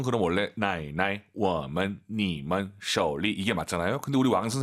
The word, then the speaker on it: 手里: 그리고 쇼가 아니라 오유는 어우입니다. 쇼우 쇼리 꼭 기억하시고요. 쇼 안 돼요.